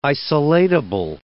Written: isolatable

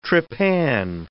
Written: trepan

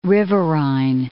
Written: riverine

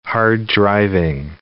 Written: hard-driving

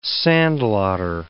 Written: sandlotter

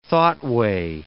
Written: thoughtway

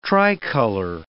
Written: tricolor

